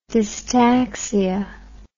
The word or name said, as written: dystaxia